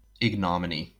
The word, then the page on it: ignominy: American English; /ˈɪɡnəˌmɪni/